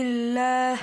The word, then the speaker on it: الله: He pronounced the lam like with a heavy sound. Is that correct? – من يهدي الله. – Ah, this is correct.